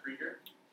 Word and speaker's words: Krieger: So, hopefully everybody saw when you came in that obviously my name looks like Kruger, but if you just take the U out, it's actually pronounced Krieger.